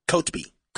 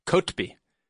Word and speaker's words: Kotb: How do you say your last name? – It's Kobe. – Kobe? – Kotb. – Kotb.